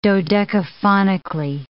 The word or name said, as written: dodecaphonically